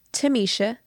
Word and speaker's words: Thomeisha: Make sure you follow me on Instagram at Tamisha, T-H-O-M-E-I-S-H-A. Make sure you spell that right. You don't have to say it right, but spell it right.